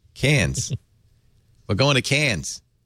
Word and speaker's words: Cairns: But I was told several times, no, it's pronounced Cairns and Mackay. And then I realized, that's just an Australian accent.